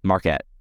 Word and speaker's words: Marquette: Marquette, no question.